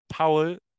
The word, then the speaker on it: Paul: Paul is pronounced Powell in German.